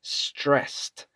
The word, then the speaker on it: stressed: The first one is stressed. Now listen to me. Stressed. Termina como con una T. Termina con ED escrito. In pronunciation, stressed.